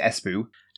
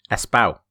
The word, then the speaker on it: Espoo: Is it Espoo? – Espao. – Oh, come on. Seriously? – Espao. Espao. Yep, so there you go.